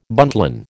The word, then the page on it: buntline: American English